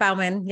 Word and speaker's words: Baumann: Yep, Baumann.